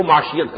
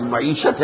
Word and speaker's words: معیشت: وہ معیشت کو معاشیت کہا کرتا تھا، اسے صحیح تلفظ آتا تھا، وہ در حقیقت لفظ معیشت ہے، وہ معاشیت کہتا تھا.